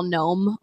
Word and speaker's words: gnome: It surprises me people misspell gnome all the time. So there is that silent G, Noeygnome.